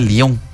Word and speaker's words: Lyon: Sí, ni siquiera se pronuncia cierto, Lion. – Me dicen lo mismo. – Ajá, se pronuncia Lyon.